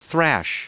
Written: thrash

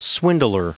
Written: swindler

